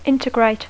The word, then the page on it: integrate: English